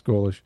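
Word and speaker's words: Golesh: It's Golish, mm-hmm. right? Isn't it right to pronounce it right? And I want to say Mike Golic Jr. I don't know why I want to say that, but it's Alex Golish, Russian immigrant.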